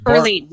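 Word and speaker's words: Irlene: I forget if that's the correct pronunciation. Erlene, right? There's Erlene. Yep.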